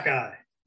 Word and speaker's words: Xhekaj: And his last name is spelled X-H-E-K-A-J. – Yes. – Jacka. – Which is phonetically Jacka. Just by reading, you can tell it's Jacka.